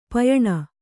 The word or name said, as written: ಪಯಣ